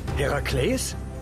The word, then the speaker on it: Herakles: Hast du einen Sprachfehler? Herakles? Ja, das. Naja, so spricht man diesen Namen aus.